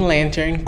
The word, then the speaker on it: lantern: Falando em lantern, sabia que a pronúncia do t aí é opcional em alguns sotaques? Na América do Norte, é muito comum que o T depois do N seja omitido. Eu falo lantern com o T,